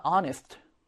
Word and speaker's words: honest: 1번 같은 경우에는 뒤에 나온 단어가 honest. 자, 철자는 자음으로 시작하지만 이 H가 발음되지 않기 때문에 모음 발음으로 시작하죠.